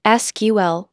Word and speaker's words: SQL: SQL, pronounced SQL, is the language used to communicate with databases.